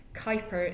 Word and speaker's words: Kuiper: So Kuiper is his name. It rhymes with hyper.